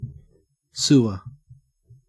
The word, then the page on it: sewer: Australian English